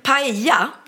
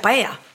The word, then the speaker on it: paella: De uttalar inte ellen, utan det blir tortilla. – Åh, vad svårt det blev nu. Ja. – Det är bara tänk att uttala inte ellen. Pernilla blir paella. Det måste ju bli det då.